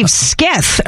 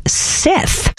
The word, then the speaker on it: scythe: Skith? I mean, I think you said this. It is actually pronounced Sith.